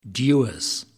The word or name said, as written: Dewars